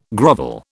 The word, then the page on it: grovel: British English